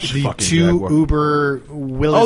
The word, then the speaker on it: Willys: Wait, so the people who made Jeeps, is that pronounced Willys? – Yes, sir.